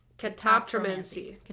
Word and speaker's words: Catoptromancy: Catoptromancy. Catoptromancy. You just gotta like throw it out of your mouth.